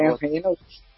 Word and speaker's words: Ryan Reynolds: É Ryan Reynolds.